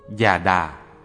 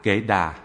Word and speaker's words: Già Đà: Già Đà, phiên âm từ phạn ngữ Gatha, dịch nghĩa là cô khởi tụng, phúng tụng, cũng đọc là kệ đà hay kệ,